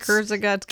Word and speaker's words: Kurzgesagt: It's Kurzgesagt.